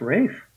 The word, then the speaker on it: Ralph: My middle name is Ralph, which I hate, but in England, it's pronounced Rafe. I like Rafe.